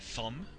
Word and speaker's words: Thom: De hecho, su nombre se escribe no Tom, T-O-M, sino es T-H-O-M, Thumb, al menos como yo le digo, Thumb Kalor.